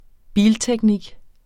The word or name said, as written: bilteknik